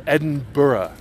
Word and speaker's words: Edinburgh: It is not called Edinburgh. It is pronounced Edinburgh. Edinburgh.